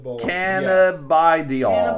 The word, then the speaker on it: cannabidiol: CBD, which for those who don't know is an acronym for cannabidol. Is that how you say it? This is what happens when you drink whiskey and Coors during a podcast. – Cannabidiol. No, let me see it.